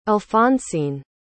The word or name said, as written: alphonsine